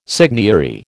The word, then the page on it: seigniory: American English